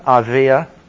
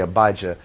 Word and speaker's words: Abijah: J, usually in Hebrew, we would say, I know in English, probably say Abijah. Aviah, the J in most of those words is pronounced as though it were a Y. Just like you don't say San Jose. J-O-S-E is San Jose.